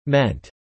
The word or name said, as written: meant